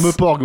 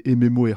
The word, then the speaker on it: MMORPG: Euh, comment, je sais plus comment s'appelait ce journaliste, en fait, qui, qui avait parlé des MMORPG rpg et il savait pas le prononcer, donc il disait Meporg.